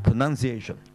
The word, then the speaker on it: pronunciation: 哦，pronounce 即係話佢就唔係 pronoun，因為肯定錯啦，noun 肯定錯，因為嗰個唔係 noun 字嚇，但係都唔好讀個 noun 字讀得咁響，而係 pronunciation。